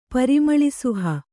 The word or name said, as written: ಪರಿಮಳಿಸುಹ